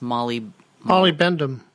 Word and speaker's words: molybdenum: Why they want the mine there is to produce copper, gold, and I can't pronounce this, molybdenum.